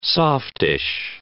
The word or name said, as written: softish